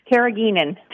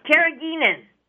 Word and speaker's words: carrageenan: Carrageenan. – Carrageenan. It's fun to say. Oh. – I believe that's how it's said. – Well, it is now.